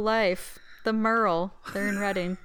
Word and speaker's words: Reading: Yeah, because the British pronounce everything weird, it's Reading.